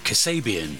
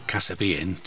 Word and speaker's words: Kasabian: We'll finish with Kasabian, who I used to call Kasabian, much to the amusement of other people that never told me I was pronouncing it wrong.